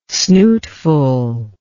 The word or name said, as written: snootful